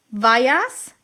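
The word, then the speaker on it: Weijers: Und der Domino-Day-Erfinder ist Robin Paul, jetzt muss ich es richtig aussprechen, Weyers, ja, also Paul wie Peter Dunn und Weyers wie Albert Wood, ja, das musst du dir auch erstmal geben.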